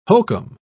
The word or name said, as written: hokum